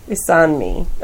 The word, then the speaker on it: Isanmi: Isanmi.